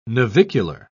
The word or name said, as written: navicular